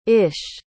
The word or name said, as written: ish